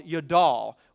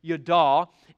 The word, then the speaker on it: yadah: The Hebrew word we studied last week for praise is a word called yadal. Y-A-D-A-H is how we would spell it in the English language, yadal.